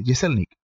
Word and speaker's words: Jeselnik: Anthony Yeselnik, así se pronuncia, Yeselnik.